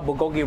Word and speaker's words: bulgogi: Um, today's special at this event, we're gonna showcase one of my most favorite uh, item is the uh, Korean style bulgogi rice bowl. Okay. – Um, how, today, how did you say that? – Uh, bulgogi. Bulgogi. Yeah, bulgogi. As bulgogi.